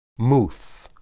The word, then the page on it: muth: Hebrew